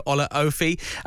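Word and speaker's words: Olaofe: Paddy Madden got two, Connor Evans and Tanto Ola... I can never get his name right. Tanto Olaofi found the net as well.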